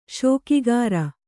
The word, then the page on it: ಶೋಕಿಗಾರ: śokigāra